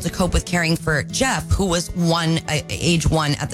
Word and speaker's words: Geoff: Ted Knobs, 83, and brother, it says G-E-O-F-F, but I think it's pronounced Jeff instead of G-O-F. – Yes, that's correct.